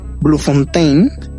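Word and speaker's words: Bloemfontein: La otra ciudad es Bluefontaine. ¿Lo dije bien? Eh, perfecto.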